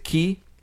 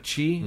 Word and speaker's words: ki: Do they pronounce it Ki? – Yes, instead of Chi. Mm-hmm. I always thought it was pronounced Chi.